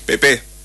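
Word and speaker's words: Pépé: ¿Cuál sílaba se pronuncia más fuerte de Nicolás Pepe? ¿Pepe o Pepe? – Pepe. – Nicolás Pepe. Porque tiene dos acentos el apellido del Marfil.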